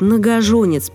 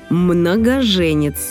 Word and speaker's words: многоженец: Еще одно слово, которое произносят неправильно из-за аналогии с однокоренным – «многоженец». Часто говорят «многоженец», потому что много жен. Но это так не работает. Правильнее отталкиваться от фразы «женился много раз». Поэтому пишем и произносим «е» – «многоженец».